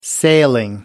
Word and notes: sailing: American English

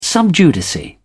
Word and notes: sub judice: British English

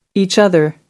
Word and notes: each other: American English

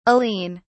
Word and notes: aline: British English

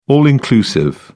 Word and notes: all-inclusive: British English

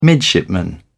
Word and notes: midshipman: British English

